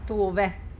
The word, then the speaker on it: Tove: Tove.